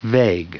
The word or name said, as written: vague